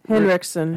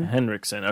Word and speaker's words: Henriksen: I know it's Lance, and then is it Henriksen. – Henriksen, okay.